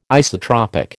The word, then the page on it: isotropic: American English